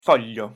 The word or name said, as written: foglio